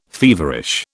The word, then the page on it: feverish: British English